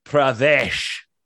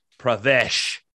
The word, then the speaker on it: Pravesh: My ship is the USS Pravesh. – Spell it. – P-R-A-V-E. That's V as in Victor. E-S-H. Pravesh.